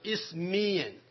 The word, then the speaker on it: Isthmian: 这个运动会叫做 Isthmian Games。 – 呢个运动会呢叫做 Isthmian。我把这个字拼给你们听哈，你们记一下啊。就是 Isthmian。 – Isthmian。 – 再讲一遍啊。 Isthmian。这个字念作 Isthmian、当时他们有这么样的一种的活动叫做 Isthmian Games。